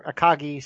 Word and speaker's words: Akagi: So she's just basically, the lady in front is like, "Here's the next one, Agaki Noburo," or Akagi, sorry.